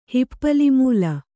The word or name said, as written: ಹಿಪ್ಪಲಿಮೂಲ